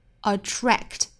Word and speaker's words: attract: 那我们还要注意一下 attract 里面前半部分的 tr tr 的发音， tract tract tract，不要发成 try，不是 attract，而是 tract attract，注意单词内部不要丢掉了的因素。